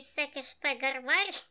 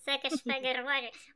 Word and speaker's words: Секешфегарварі: в місті Секешфегарварі. Секешфегарварі. Лай. Ти все правильно сказала, ти просто засміялась в кінці, сама себе фіксувала.